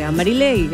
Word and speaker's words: Mariley: se pronuncia mi nombre. Algunos me dicen Marilei. Marilyn, Madeleine, Marilei, así de sencillo, así de fácil, M-A-R-I-L-E-Y, Marilei, lo máximo, me encanta acompañarla, no me cambien el nombre, mi nombre es bonito.